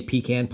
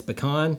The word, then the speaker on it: pecan: And God help you if you say pecan pie. – Damn straight. – You're fucking dead to me. – Yeah. – That is not how you pronounce it. It's pecan.